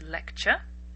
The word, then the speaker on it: lecture: I've talked in the past about words that end in T-U-R or T-U-R-E. The T is actually pronounced like a CH. Lecture. Lecture. We don't say lecture. Lecture. Lecture. Lecture. It sounds a little bit like picture.